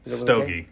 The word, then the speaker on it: stogie: How do you say that? Stokey? – Stogie. – Stogie, okay. – Yeah. – I wrote it down with a K for some reason.